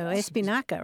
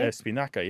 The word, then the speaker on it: espinaca: Spanish keeps it though. Espinaca, right? Espinaca, yeah.